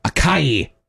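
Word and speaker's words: açaí: Or acai. A-C-A-I. – How do you say it? Acai? – Acai.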